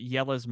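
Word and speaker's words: Jelle's: Go check out Yella's Marble Runs on YouTube. It's spelled Jelles Marble Runs.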